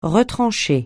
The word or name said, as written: retrancher